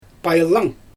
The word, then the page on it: pajlang: Xyzyl